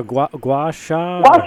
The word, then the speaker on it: gua sha: Gua sha. – Gua sha.